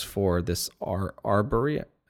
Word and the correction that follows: Arboria: Am I saying that right? Easy for you to say. I can't. – Arboria. – Arboria. Yeah.